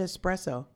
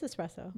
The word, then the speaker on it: espresso: You said it right. – I said it right? – You said espresso. – It is espresso. – No, I know. But have you ever heard people say it the wrong way? – No.